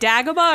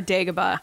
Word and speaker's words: Dagobah: Is it Dagobah or Dagobah? – I don't know. I think it's kind of that tomato-tomato thing.